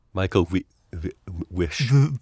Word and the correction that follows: Michael Wisch: Michael Vish, who was actually very particular, by the way, about the pronunciation of his name.